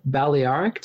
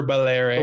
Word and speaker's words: Balearic: And next week, we'll be back to talk about the difficult to pronounce Balearic. Did I get that right? Balearic or Balearic? – Balearic. – Balearic. I got it totally wrong.